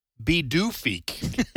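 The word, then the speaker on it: Pituffik: And I guess Greenlandic is just one of those languages that's just baffling to me because it's spelled, the new name is P as in Paul, I-T-U-F-F-I-K, and it's pronounced Bidoofik.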